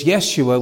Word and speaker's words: Yeshua: And yet this word is Yeshua with an H at the end. Makes no difference in the pronunciation.